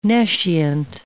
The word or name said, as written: nescient